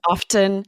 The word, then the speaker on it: often: Lindsay says often. Yes. I say often with a silent T.